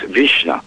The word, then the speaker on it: visna: VISTA didn't get that name, V-I-S-N-A, until the 1930s when the Icelandic farmers were watching their flocks of sheep being decimated, and VISTA is an Icelandic word. It means, it's pronounced Vishna, and it means wasting.